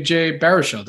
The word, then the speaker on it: Baruchel: Hey, that also includes you just announced a proud Canadian, Jay Baruchel.